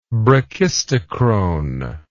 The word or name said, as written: brachistochrone